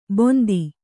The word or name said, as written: ಬೊಂದಿ